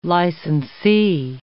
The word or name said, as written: licensee